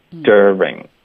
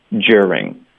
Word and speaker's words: during: 所以一般在美式发音中我们就发 dir，是直接进入到 er 的音 during。那甚至也有人会把这个 de 跟 ye 用一个变音技巧来处理，发 j，一个 j 的音，我们之前有学过 de 连到 ye 的音可以发 j，那就发 during。